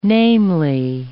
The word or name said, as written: namely